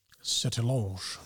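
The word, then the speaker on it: Sertillanges: I don't know how to pronounce his surname in French. – Sertilonge. – Yeah, there you go.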